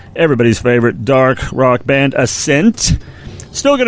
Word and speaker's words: ASNT: A-S-N-T. For, like in excess, kind of, for a multitude of reasons, the name, of course, is still pronounced Ascent.